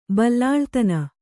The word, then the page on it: ಬಲ್ಲಾಳ್ತನ: Kannada